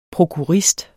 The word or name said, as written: prokurist